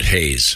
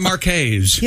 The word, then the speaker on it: Hayes: Is it Mark Hayes or Mark Hez? It sounds like you're saying Mark. New word, Hayes.